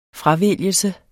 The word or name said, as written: fravælgelse